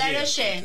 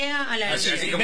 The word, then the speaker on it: Alaroyé: Alaroyé, tomá, lo dije bien, Alaroyé. – Alaroyé, con Y, Alaroyé.